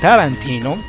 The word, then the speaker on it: Tarantino: Todos aquellos fanáticos del surf y de cuarantín y de cuarantino, ¿eh? ¿Quién es ese? No. – No mames. – Ah, cálmate, solo lo pronuncié mal una vez. Bueno, de Quentin Tarantino.